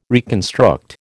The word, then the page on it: reconstruct: American English